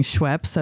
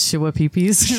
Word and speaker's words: Schweppes: The way you said his name reminds me of like the people who are mispronouncing Schweppes as Shwapeepees.